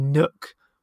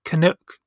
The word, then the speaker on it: Nook: I couldn't really tell you the names of any of the characters, apart from, I think his name is meant to be Nook, but everyone in their Australian accent seemed to pronounce it like Canuck, which made it sound like he was a Canadian, but he's not.